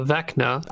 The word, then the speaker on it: Vecna: Next one, Hand of Venica. – You did it again. Hand of Vecna. – Ah, dang it, I did it again! Ah, adding extra syllables.